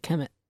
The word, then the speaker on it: Kmet: K-M-E-T. – Oh, Kemet. – They pronounced it Kemet. – That's interesting. So kind of like Kermit, but with an M.